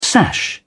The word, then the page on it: sash: British English